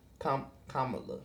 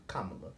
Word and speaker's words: Kamala: Kamala. – Thank you. – There you go. Kamala.